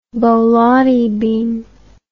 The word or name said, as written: borlotti bean